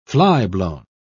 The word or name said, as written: flyblown